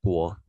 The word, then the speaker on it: Guo: So in Chinese, my name reads as Jocelyn Guo, or actually Guo Jocelyn, because in our country, the last name goes first.